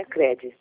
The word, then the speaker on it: cred: O D é mudo, né? Cred. – Isso mesmo. Tudo junto, poupa cred junto, só que o D mudo.